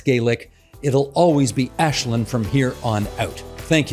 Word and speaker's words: Aislinn: Her name is pronounced Ashlyn. It's Gaelic. I know many people didn't know how to pronounce it on election day either. They just needed to know where she was on the very long ballot. For future reference, remember, it's Ashlyn."